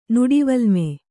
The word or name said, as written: ನುಡಿವಲ್ಮೆ